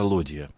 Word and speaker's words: лодья: Правильно говорить, не ладья, а лодья.